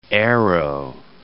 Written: aero

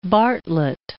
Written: Bartlett

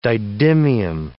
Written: didymium

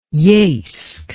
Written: Yeisk

